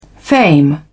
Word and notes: fame: British English

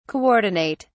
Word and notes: coordinate: British English

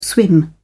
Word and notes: swim: British English

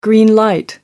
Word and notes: green light: British English

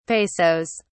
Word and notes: pesos: British English

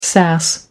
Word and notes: sass: American English

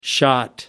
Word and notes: shot: American English

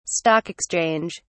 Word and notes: Stock exchange: British English

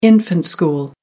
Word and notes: infant school: American English

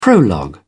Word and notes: prologue: British English

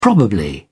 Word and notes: probably: English; British English

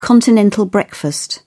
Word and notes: continental breakfast: British English

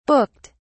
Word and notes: booked: British English

